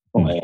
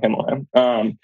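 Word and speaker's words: Ximalaya: Himalaya was, and it's spelled like XI, Himalaya, kind of like Himalaya.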